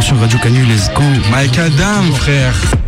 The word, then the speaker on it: Micadam: Mike Adam, c'est comme ça que vous prononcez Mike Adam, si si.